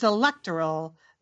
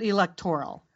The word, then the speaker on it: electoral: Okay, for the record, the accent is on the second syllable, not the third. – Oh. – So, Julie, you did that correctly. It's electoral, not electoral.